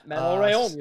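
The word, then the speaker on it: Manon Rhéaume: A quick anniversary, I think it was yesterday or today, 28 years ago, we might as well acknowledge it, Manon Raymond, I believe I'm pronouncing that correctly. – Yeah, Manon Raymond, you're saying it right, yeah.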